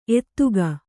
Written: ಎತ್ತಿಗ

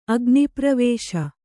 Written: ಅಗ್ನಿಪ್ರವೇಶ

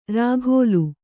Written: ರಖೋಲೆ

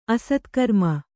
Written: ಅಸತ್ಕರ್ಮ

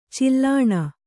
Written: ಚೀಲಣ

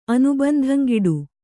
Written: ಅನುಬಂಧಂಗಿಡು

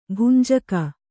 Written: ಭಂಜಕ

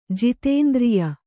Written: ಜಿತೇಂದ್ರಿಯ

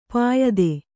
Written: ಪಾಯ್ತ

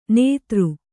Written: ನೆತ್ತರ

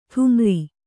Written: ಢಮಾರ್

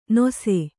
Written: ನೊಸೆ

